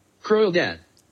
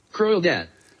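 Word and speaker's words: Kraulidat: Croyle Dad. – What? – Croyle Dad.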